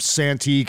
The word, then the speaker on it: Santee: the former mayor of Santee, California. I think I'm pronouncing that. – That is correct. Yeah, okay. I lived near Santee, not in it, but I lived in El Cajon, which is a neighboring community.